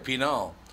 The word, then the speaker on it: penal: Well, in Spanish, it would be penal. So. – Oh, so I said it right. – So you had it right, penal.